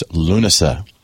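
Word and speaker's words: Lúnasa: In Celtic, in Irish, it's Lunasa. Your pronunciation is just another version of it, and it's perfectly accurate.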